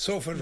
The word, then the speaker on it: Salford: I'm going to the tour starts in Salford. Am I pronouncing that right? – Salford, right? – That's close enough.